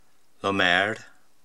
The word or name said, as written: Lomeyr